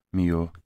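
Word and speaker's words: Milhaud: É Milo. É assim? Mio. miou Uh, é, eh, é como o gato o, O gato miou sim.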